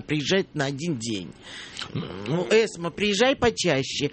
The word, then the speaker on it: Эсма: Эсма, я ударение правильно ставлю. – Эсма, да.